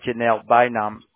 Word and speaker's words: Janelle Bynum: And what did you read, see, or heard about Janelle Bynum make you more or less likely to support her for Congress? Did I say that correctly, ma'am? – Mm-hmm. – Or close? – Yes.